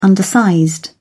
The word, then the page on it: undersized: British English